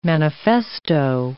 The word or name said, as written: manifesto